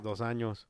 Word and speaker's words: Dos Anjos: That's how you say it in Spanish. – What? – Dos Anjos. – Oh, yeah, 2 years. 2 years, yeah. It is, Dos Anjos.